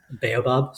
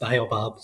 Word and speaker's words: Baobab: The two ways that most people pronounce it is Baobab Studios. That's how I would say white people would pronounce it. And, you know, like myself, you know, and but there's also the other way of Baobab Studios that is pronounced.